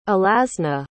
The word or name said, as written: alazne